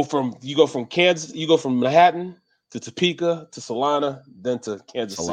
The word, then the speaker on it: Salina: My buddies are in Salinas, Kansas. – Salina. Yep. – Oh, is that Salina. How you say it? – Salina. Yeah, Salina.